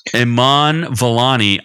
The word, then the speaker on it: Vellani: I found Miss Marvel, Iman Vellali. What the hell's her name? I keep mispronouncing it. Iman Vellani.